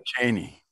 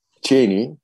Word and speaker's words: Cheney: ABD Temsilciler Meclisi üyesi Liz Cheney, Cheney mi? Nasıl okunuyor? – Cheney. – Cheney,